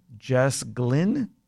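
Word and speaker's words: Jess Glynne: Jess Glynn? Is that how you say it? – Yes.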